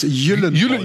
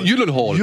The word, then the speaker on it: Gyllenhaal: Jake, außerdem spricht man das Gyllenhaal. Gyllenhaal. Gyllenhaal.